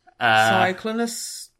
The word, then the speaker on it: Cyclonus: Which is how normal people pronounce Cyclonus, apparently. – Yep. Cyclonus. – Cyclonus, don't pronounce the vowels.